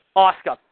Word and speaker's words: Asuka: But as far as I can't pronounce her name, Asuka or whatever. – Asuka.